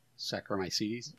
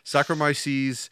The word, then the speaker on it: Saccharomyces: I know I'm pronouncing this wrong. – Saccharomyces? – Yeah, wow. I know there's a camera in my basement here somewhere. You can see over my shoulder. Saccharomyces cerevisiae.